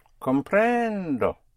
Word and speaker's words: comprendo: Comprendo. Always on the syllable before last, on the penultimate syllable. If there are only two syllables, you push down on the first. Otherwise, it's always on the penultimate syllable.